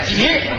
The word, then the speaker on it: 给水: 给水，这字读音就念挤，就是给字。